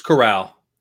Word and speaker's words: Corral: Is it Corral or Coral or like how do you say his name? – It's Corral. – Corral.